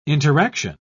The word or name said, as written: interaction